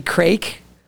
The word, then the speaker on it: craic: I go, yeah, the crack balls. I even pointed at it. Like I know how to say the word. She goes, that's actually craic.